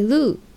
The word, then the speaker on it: loo: うーん、あとイギリスではトイレットという言葉以外に LOO と書いてルーという言葉でトイレという意味になります。